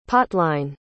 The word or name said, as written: potline